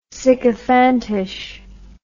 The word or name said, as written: sycophantish